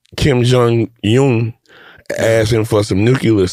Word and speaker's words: Kim Jong-un: He make the un rhyme with Jung. – There you go, you got it. – No. Yeah. So Kim Jong-un.